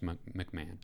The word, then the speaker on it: McMahon: But Murray State is coached by, how do you say his last name? – McMahon? – Yeah. Matt McMahon, probably. – McMahon? – I think it's just McMahon.